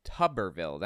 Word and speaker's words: Tuberville: Thank- Tuberville. Tuberville. That's how it's pronounced. Okay. Like bathtub. Tuberville. Anyway.